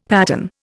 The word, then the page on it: patten: Indian